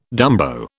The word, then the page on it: dumbo: British English